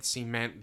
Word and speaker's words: cement: I did not know you pronounced cement like that. – Cement. – So you've been doing it cement, and I've just pronounced it cement my entire life.